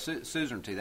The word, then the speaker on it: suzerainty: And basically, the deal was, and if you want to know it, suzerainty, that's a big three-syllable word that basically means paid protection.